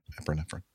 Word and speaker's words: epinephrine: Epinephrine.